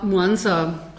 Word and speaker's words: Mwanza: Ja, Mwanza, das wird so ein bisschen äh, nicht so wie so das, das harte W, mhm. sondern äh, so ein bisschen überrollt sozusagen hatte ich nämlich ganz vergessen, dass es eine Partnerstadt ist.